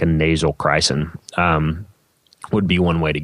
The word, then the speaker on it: Chrysin: And the one you'd want to look into is Crysin. It's spelled Chrysin.